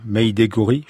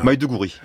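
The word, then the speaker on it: Maiduguri: Mais on va aller maintenant dans la capitale du Borno, Maïdegouri, je prononce très mal. Hein. – Maïdegouri.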